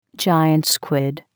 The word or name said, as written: giant squid